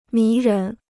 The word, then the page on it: 迷人: mí rén